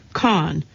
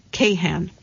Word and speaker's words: Kahan: To be well-versed in conology, You should know that it's pronounced Khan, not Kahan. I just say one of the A's is silent, Khan says.